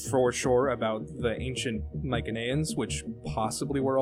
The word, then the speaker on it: Mycenaeans: We know they were centered on Crete, I believe, because there's Well, that's the Mycenaeans, technically, or Mycenaeans, if you want to call it like that. But the Greeks didn't have soft C sounds in their language.